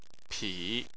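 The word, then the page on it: pi3: tone 3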